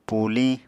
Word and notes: púliñ: apatani

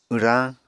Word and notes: wrañ: Apatani

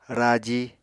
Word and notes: raji: Apatani

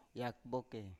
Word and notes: yakboke: Biak

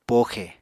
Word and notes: poxe: apatani

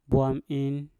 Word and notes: bwam in: Mpur

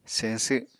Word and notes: señsw: Apatani

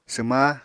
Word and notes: swma: apatani

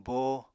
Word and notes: bóo: apatani

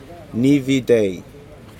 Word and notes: nivitai: Aneityum

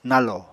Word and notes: nalò: Apatani

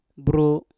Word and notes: bru: Mpur

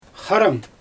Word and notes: xarɯm: Xyzyl

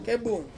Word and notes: kebung: Ansus

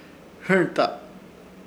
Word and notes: Hɨrta: Ishir; Chamacoco

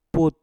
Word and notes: put: mpur

